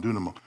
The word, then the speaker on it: dunamo: That's their A, that's their M, that's their E, that's another N, and that's a long O sound, dunamo.